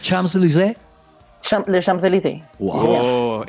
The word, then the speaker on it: Champs-Élysées: París, Champs-Élysées.